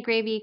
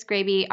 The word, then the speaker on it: Graebe: We are on socials, Jenni Graebe, Chris Graebe.